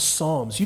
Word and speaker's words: Psalms: Let me show you the book of Psalms. It's pronounced Psalms, like Psalms. Not the P is silent. That's all right.